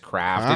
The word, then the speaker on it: CRFT: It's just craft. It's one of those dumb ones where they take all the vowels out. – C-R-F-T.